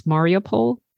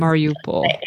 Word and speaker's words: Mariupol: Tell me what family, so first of all, is it, I don't know how to pronounce the name of the city properly. Tell me again, it's Mariupol? – Mariupol, I think is Mariupol. – Anglicized. Mariupol is the...